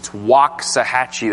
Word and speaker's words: Waxahachie: This weekend, if the Lord allows it, I will be in Waxahachie, that's the proper pronunciation, Texas, south of Dallas, to do 6 lessons.